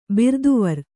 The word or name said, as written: ಬಿರ್ದುವರ್